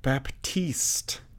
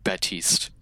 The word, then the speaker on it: Baptiste: And I saw on Reddit that somebody was saying that it's a silent P. So it's like Baptiste or something instead of Baptiste.